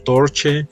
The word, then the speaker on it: Torche: Eh, y bueno, pues dentro de las bandas que han grabado, Además de Daughter está Torche. ¿Sí se pronuncia Torche? Torch. – Ajá, Torche.